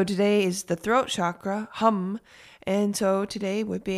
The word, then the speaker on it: ham: Today's mantra is actually going to be the word hum, which is spelled H-A-M, but pronounced hum, like as in humming.